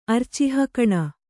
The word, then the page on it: ಅರ್ಚಿಃಕಣ: arcihkaṇa